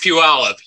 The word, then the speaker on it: Puyallup: It's Puyallup.